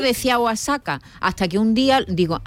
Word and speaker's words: Oaxaca: Pero a mí me ha recordado también Oaxaca. – A Oaxaca.